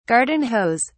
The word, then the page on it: garden hose: British English